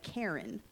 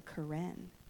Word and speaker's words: Karen: When you look at it written down, it looks like Karen, but it's pronounced the Karen.